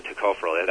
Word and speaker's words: tocopherol: Yeah, anything that has a vitamin E supposed content, it's always alpha tocopherol, right? – Right, right. And I know that tocopherol, that's the correct pronunciation of it. I know you're saying it right, but I felt that it, you know, saying tocotrienol, tocopherol kind of helps.